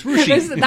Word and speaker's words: Russia: It's spelled Russia. It's Rushi.